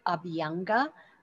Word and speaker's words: Abhyanga: If you Google Ayurvedic oil massage or self oil massage, and it has a fancy Sanskrit name called Abhyanga. One of my friends says it rhymes with I'll be younger.